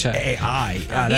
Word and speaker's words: Pichai: The most he did as far as talking AI was make a joke that the last two letters of his name, Pichai, are A-I. – Sound like AI. – It is Pichai.